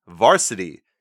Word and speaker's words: VRSTY: And last up this evening, we are going to be talking about the band Varsity, and it's spelled V-R-S-T-Y, and their EP's name is Black, but also take out that A there, so it's B-L-C-K.